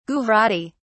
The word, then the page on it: Gujarati: British English